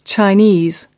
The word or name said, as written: Chinese